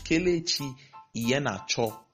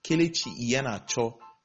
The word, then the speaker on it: Kelechi Iheanacho: It's Kelechi Iheanacho. Kelechi Iheanacho, not Iheanacho, the way commentators have been calling it.